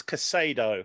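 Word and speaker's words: Caicedo: I will get you to say his name properly one day. – Kotedo. – It's Casado. – Casado. Oh, there you go. – He's not Italian.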